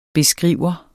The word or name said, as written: beskriver